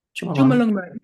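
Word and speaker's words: Chomolungma: Chumalungma? – Chumalungma, yeah, exactly.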